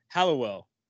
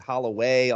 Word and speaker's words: Hallowell: You did. You know, that's really good because a lot of people don't get it. They come up with Holloway, all these other things.